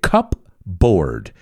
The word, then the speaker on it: cupboard: And a cupboard, this is an interesting word because se escribe cupboard. Pero a cupboard, así se pronuncia, is where you put your dry goods.